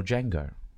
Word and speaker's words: Django: No, Franco Nero, the guy at the bar, is the original Django. – Oh. – The one who goes, right. What's your name? – Yeah. – He goes, the D is silent.